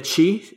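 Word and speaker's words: Qi: creo que se pronuncia, chi, hasta donde entendí.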